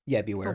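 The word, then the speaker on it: Bewear: How do I say that? Beware. – Beware.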